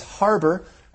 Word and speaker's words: Harbour: That's right, my surname, my family name is Harbour, and that is spelled the British way, H-A-R-B-O-U-R. – And what's my family name?